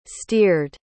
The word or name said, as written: steered